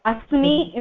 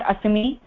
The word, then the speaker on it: asmi: And asmi is the proper pronunciation. It is not asmi. You also cannot use asti because you are referring to your own self. So you should be saying asmi.